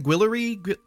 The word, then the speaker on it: Guillory: How, how do you say her name? Guillory.